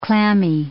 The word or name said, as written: clammy